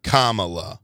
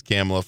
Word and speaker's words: Kamala: First of all, I've never heard it definitively said that her name is Kamala. – Yeah. – Kamala. I've said Kamala Harris, yeah. like, you know, throughout all this. But clearly, I will say, Tucker Carlson intentionally mispronounced it as Kamala for all these years or for all these months.